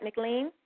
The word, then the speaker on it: McLean: Am I pronouncing it right, McLean? – Yes, you are.